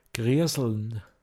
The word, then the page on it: Misdgrai: Pinzgauer Mundart